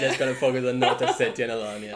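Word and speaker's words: North Ossetia–Alania: Her name is Vlada and she's from North Ossetia and Alania.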